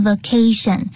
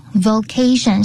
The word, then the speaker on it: vocation: 就是呢，我比较喜欢的职业，这边职业是 vocation，那千万不要念成 vacation，就只差一个这个字母而已，就 V-O 跟 V-A， vocation 是职业。